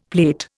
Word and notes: plate: Indian

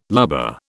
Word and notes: lubber: British English

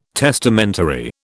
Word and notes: testamentary: British English

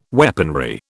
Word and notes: weaponry: British English

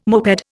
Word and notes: moped: Indian

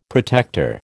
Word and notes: protector: American English

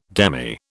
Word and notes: demy: British English

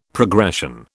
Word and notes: progression: British English

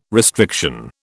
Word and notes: restriction: British English

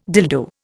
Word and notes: dildo: Indian

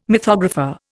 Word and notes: mythographer: Indian

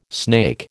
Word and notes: snake: American English

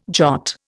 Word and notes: jot: Indian

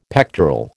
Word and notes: pectoral: American English

